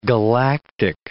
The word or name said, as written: galactic